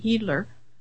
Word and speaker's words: Hiedler: Now, my Austrian friend tells me that's how it's pronounced. It's H-I-E-D-L-E-R, and it's pronounced the long E, Heedler.